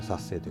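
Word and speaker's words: 殺青: 殺生青青く殺すと書いて、うん、殺生と読むんですけど、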